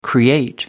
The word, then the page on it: create: American English